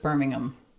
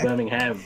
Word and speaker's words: Birmingham: How'd I do with Birmingham? – You did. That was actually about pronouncing. That was really impressive. It wasn't the Birmingham, which I expect usually from Americans.